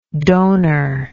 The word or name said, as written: donorship